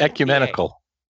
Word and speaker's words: ecumenical: And I'm hoping that it's a little more equiminable. That's not how you pronounce the word I wanted to say. Then I'm imagining. – Ecumenical. – Yep.